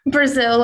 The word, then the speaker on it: Brazil: Brazil, like the country.